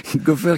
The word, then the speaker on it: Goffer: Après, je l'ai appelé Goffner et Goffer. Non, c'est Golfer, c'est ça? – Goffer qui signe le featuring du morceau que nous venons d'entendre il y a quelques instants.